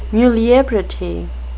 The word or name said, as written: muliebrity